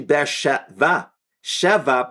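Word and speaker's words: Be'er Sheva: Here, it's actually Ber Sheva, because this, with Yitzchak, it's the seventh well.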